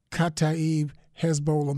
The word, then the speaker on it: Kataib Hezbollah: The United States uh, mounted an air raid attack on the Khatib how do you say it again? Kataib Hezbollah militia, which was then followed by pro Iranian militiamen attacking the U.S.